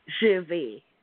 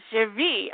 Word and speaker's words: Gervais: How do you say your name? Gervois? – Gervais. – Gervais. Oh my, I'm so terrible. – Like, Ricky Gervais. You know that comedian, Ricky Gervais. – Got it. – It's Gervais. – The same pronunciation.